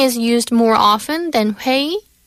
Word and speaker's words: 회의: So it's 회의.